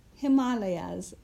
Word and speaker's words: Himalayas: And we pronounce the Himalayas, Himalayas. And I know that's pronounced differently by some people.